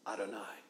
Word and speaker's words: Adonai: they would read the word Adonai in Hebrew. A-D-O-N-A-I, Adonai, depending on your good Hebrew pronunciation, Adonai.